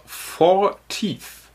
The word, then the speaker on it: Fortit: Ähm, Fortit schreibt man sie. Man spricht es allerdings aus. In Lautschrift schrieb Gunnar Fortith. Vier wie die vier, also vor wie die vier, tief wie die Zähne.